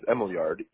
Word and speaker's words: Emiljard: But my real name is Emiljard. E-M-I-L-J-A-R-D. – Okay. Emiljard? Is that how you pronounce it? Okay. – Yes. – And what's your last name? – The last name is Ismailukaj. I-S-M-A-J-L-U-K-A-J.